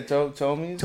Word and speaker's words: Ptolemies: It has a P. I'm assuming the P is silent. – Yeah, Ptolemies? – Ptolemies?